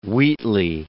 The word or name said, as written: Wheatley